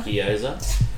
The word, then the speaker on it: gyoza: Gyoza.